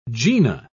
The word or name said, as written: gena